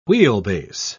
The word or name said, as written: wheelbase